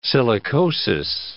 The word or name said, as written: silicosis